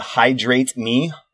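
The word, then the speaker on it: Hidrate Me: Another one that was cool on there that I saw is called Hydrate Me, and it's spelled like this, HIDRATE, Hydrate Me.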